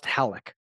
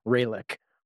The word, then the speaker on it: Ralek: Pronounced Ralek. The R is not an R in Portuguese. So please don't call him Ralek or he will choke the fuck out of you.